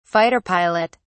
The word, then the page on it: fighter pilot: British English